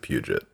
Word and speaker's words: Puget: Puget? Is that how it's pronounced? – That's how I've heard it pronounced. What did you think it was? – I always thought it was Puget. – I'm pretty sure it's Puget Sound. – Yeah, I have no reason to think I've been saying it right, so I believe you.